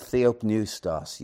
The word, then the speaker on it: theopneustos: The Greek word theopneustos, you spell that T-H-E-O-P-N-E-U-S-T-O-S, that's in English. Theopneustos, the P is normally silent,